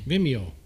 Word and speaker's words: Vimeo: It was it Vimeo or whatever? – Venmo. I almost said Venmo. – I don't know how you pronounce it. – I know what you're talking about, though. Sony, I think. – We got it up on my TV. – Vimeo. – Yeah, Vimeo. That's it.